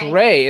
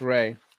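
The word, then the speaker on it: Rai: No, it's Ray. – It's R-A-I. It's pronounced Ray.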